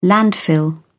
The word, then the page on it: landfill: British English